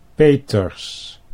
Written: Peters

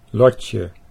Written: Lotje

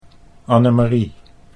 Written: Annemarie

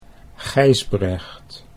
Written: Gijsbrecht

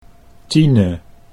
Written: Tine

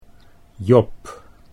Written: Job

